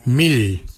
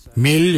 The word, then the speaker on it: mily: For eyes, we say mil. Mil is your eyes. Mil is spelled M-I-L-Y. For eyebrows, we say mimbrun. Mimbrun is spelled M-I-M-B-I-R-R-N.